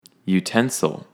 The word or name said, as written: utensil